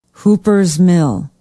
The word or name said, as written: hoopers mill